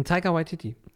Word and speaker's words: Taika Waititi: Ähm, Taika Waititi. – Taika Waititi ähm, ist der Regisseur und freut sich auch schon noch im Bauch, dass er das machen darf.